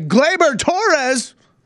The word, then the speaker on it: Gleyber Torres: That's a 2-run shot by Gleyber Torres.